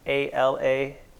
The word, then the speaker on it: Phalangas: Yeah, Nathan Phalangas, F as in Frank, Phalangas.